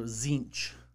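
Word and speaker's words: Tzeentch: The first T is silent. – Of course, every first T is silent, apparently. – Right. Yeah. – Yeah. – Yeah. So Zinch.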